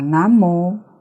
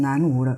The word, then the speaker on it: 南无: 把南无念成南无了，变成南半球没有佛法。这个名号是从印度梵文音译过来，要念汉唐时候的音才较准确，这是古音。可见这个世间仍然有很多人对佛法依然存有误会。这一句“ 南无